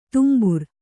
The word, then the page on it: ತುಂಬುರ: tumbura